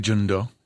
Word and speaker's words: Jeong-do: I say Jeong-do. – Do, yeah. Okay. Do, like Play-Doh.